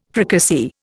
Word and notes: fricassee: Indian

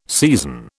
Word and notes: season: British English

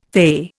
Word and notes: fay: Indian

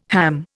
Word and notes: ham: Indian

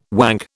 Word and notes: wank: British English

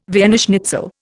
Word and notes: Vienna schnitzel: Indian

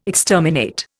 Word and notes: exterminate: Indian

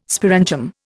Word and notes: sporangium: Indian

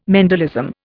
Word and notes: Mendelism: Indian

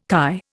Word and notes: KY: Indian

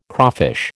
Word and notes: crawfish: American English

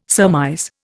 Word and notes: surmise: Indian

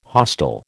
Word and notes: hostile: American English

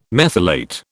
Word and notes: methylate: British English

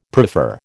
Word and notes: prefer: American English

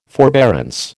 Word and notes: forbearance: American English

